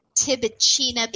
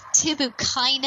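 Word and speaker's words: Tibouchina: I pronounce it Tibicina because it sounds pretty. China sounds pretty, doesn't it? The proper way to pronounce this is Tibucaina. I don't think that sounds as pretty, so I'm going to call it Tibicina.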